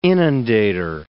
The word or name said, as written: inundator